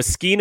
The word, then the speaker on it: skein: So a skein of yarn, S-K-E-I-N, but it's pronounced the exact same way, is an oval-shaped oblong bundle of yarn.